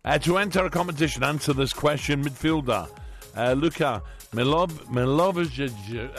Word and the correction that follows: Milivojevic: How do you say it, Danny? – Milivojevic. – Milivojevic. – I do struggle with those Serbian names.